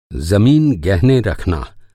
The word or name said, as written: गहना